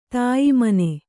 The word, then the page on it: ತಾಯಿಮನೆ: Kannada